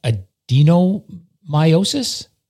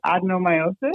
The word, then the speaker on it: adenomyosis: So it's pronounced many different ways. – Okay. – But, you're definitely not the only one to call it adenomyosis. It can be called that or adenomyosis.